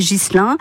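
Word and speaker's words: Ghislain: Aujourd'hui, nous fêtons les Ghislains, les Ghislaines ou Guilain-Guilaine. Hein, certains ne prononcent pas le S, mais on n'oublie pas non plus les Virgiles, puisque c'est leur fête aujourd'hui.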